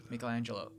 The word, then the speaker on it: Michelangelo: Michelangelo.